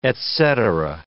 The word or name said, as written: et cetera